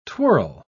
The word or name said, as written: twirl